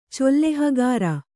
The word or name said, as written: ಚೊಲ್ಲೆಹಗಾರ